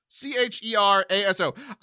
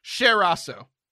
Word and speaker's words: Cheraso: Cheraso. I'm going to pronounce it like the singer Cher. Cheraso. – That's the correct pronunciation.